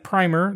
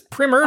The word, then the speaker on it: primer: And although it looks like primer, the proper way to pronounce it is primer.